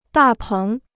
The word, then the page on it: 大棚: dà péng